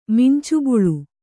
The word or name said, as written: ಮಿಂಚುಬಳ್ಳಿ